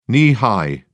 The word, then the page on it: knee high: British English